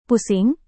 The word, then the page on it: pusing: Indonesian